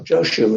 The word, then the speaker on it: Joshu: Joshu is his name, as it's pronounced in Japanese.